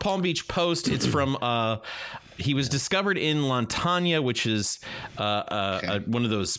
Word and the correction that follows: Lantana: Lantania. – I'm probably totally mispronouncing that. – Lantana. – Lantana. Yeah, Lantana, I think, is probably closer to it.